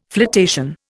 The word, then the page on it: flotation: Indian